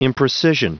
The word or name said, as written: imprecision